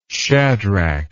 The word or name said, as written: shadrach